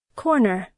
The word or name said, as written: corner